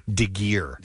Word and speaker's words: Degeare: Deguerre. So that's French. D-E-G-E-A-R-E. And that gets mispronounced.